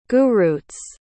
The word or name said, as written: gurutz